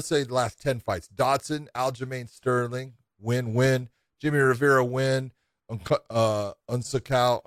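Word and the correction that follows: Assunção: Asunciao gets a win against him.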